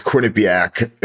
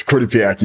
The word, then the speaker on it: Quinnipiac: And for those listening, that is Quinnipiac. Everyone's been butchering the name and all these polls and everything going on recently. But yes, it's Quinnipiac University.